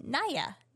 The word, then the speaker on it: Naya: It is said like Naya.